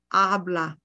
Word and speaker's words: habla: H is silent. H equals nothing. So that's why we say, habla usted español.